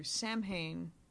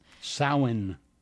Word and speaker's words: Samhain: Samhain. – Yeah, I know, but they don't say it like that. – They don't. – No, they don't. And you can yell at them. I'm not going to. – I will. – But the I just did. – You guys, you're saying it wrong.